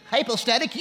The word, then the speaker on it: hypostatic: Let me say it correctly. Hypostatic union, okay? You can read about that. H-Y-P-O-S-T-A-T-I-C, hypostatic union.